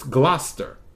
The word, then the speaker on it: Gloucester: Why is she pronouncing it like that when it looks like Gloucester? But that's not how you pronounce it. It's Gloucester. Go figure.